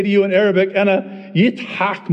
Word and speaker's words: Yitzhak: In Hebrew, it's pronounced Yitzhak.